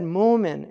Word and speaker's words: Mu'min: So they said Mumin instead of Mu'min.